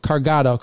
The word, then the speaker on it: Cargado: They are, you guys are the founders and the owners of, and let me pronounce it correctly, Cargado, correctly? – Yes, that's correct.